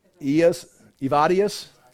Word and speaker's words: Evodius: And he's now, just like Timothy is in Ephesus for Paul, like we're talking about on Sundays, how do you say that, would you say? I've tried to do some pronunciation things before I start talking. Evodius? Okay, very good.